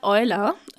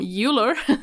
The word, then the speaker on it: Euler: Yes, and this week's quote is by Leonard Euler, or as people from England, English-speaking people, pronounce him Euler.